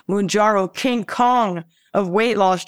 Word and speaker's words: Mounjaro: Is it pronounced Mounjaro? – Mounjaro, yes.